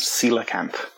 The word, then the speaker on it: coelacanth: It's pronounced coelacanth.